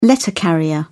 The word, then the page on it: letter carrier: British English